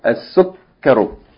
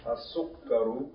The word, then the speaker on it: السكر: طيب say it one more time Don't make the second syllable long. There's no long vowel. As-sukkaru. – As-sukkaru.